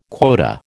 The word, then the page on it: quota: American English